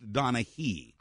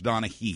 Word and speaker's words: Donaghy: It's spelled Donaghy, G-H-Y, but it's pronounced Donaghy. – I think I've heard it pronounced both ways. – Well, those guys are wrong. It's okay. – Pronounced Donaghy.